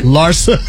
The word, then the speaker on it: Larsa: Larsa. That's how you say her name.